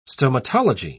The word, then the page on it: stomatology: British English